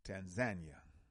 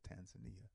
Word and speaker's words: Tanzania: Some people pronounce it Tanzania, but I was informed that they pronounce it Tanzania. And so if that's what the brothers and sisters pronounce it, I'm going to pronounce it the way they do.